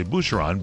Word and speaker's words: Bucheron: And out will come a picture of Dancing with the Stars and Tom Bergeron, because you didn't say it correctly. But if you say Boucheron, B-U-C-H-E-R-O-N, that's a goat cheese from the Loire Valley in France, they'll tell you all about the cheese.